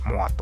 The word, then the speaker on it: MOOAR: はい、ザ・キッズ・ア・オール・ライトという、えー、新しいコレクションは、モアというね、 MOOAR と書いて、モアと呼びますけども、